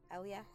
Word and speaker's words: Elia: and is that how you say her name? Elia? – Sure. – Elia? – Yep.